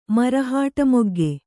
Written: ಮರಹಾಟಮೊಗ್ಗೆ

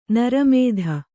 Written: ನಿರ್ಮದೆ

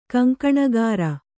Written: ಕಂಕಣಗಾರ

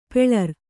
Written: ಪುಲ್ಲೋರಿ

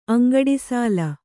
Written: ಅಂಗಡಿಸಾಲ

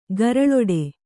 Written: ಗರಳೊಡೆ

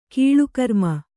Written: ಕಾಲಕರ್ಮ

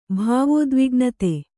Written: ಭಾವೋದ್ವಿಗ್ನತೆ

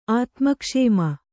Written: ಆತ್ಮಕ್ಷೇಮ

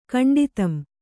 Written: ಕಂಡಿತಂ

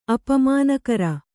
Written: ಅಪಮಾನಕಾರಿ

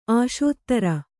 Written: ಅಸ್ತ್ರಿ

